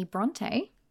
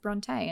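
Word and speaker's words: Brontë: Her dad was like, oh, let's just put two little dots on the end of our name to make people understand that it's pronounced Bronte and not Bront with an E.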